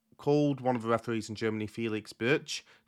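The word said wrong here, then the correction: Brych: Turns out it's Felix Brück. I think it's how you say his name, because that's what Derek Ray said on commentary before this game, and it was the same referee.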